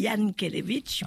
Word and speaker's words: Jankélévitch: Alors, effectivement, certains disent Jankélévitch, moi je dis Jankélévitch, puisque voilà. – Oui, le J se dit, se prononce Y, effectivement.